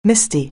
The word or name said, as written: misty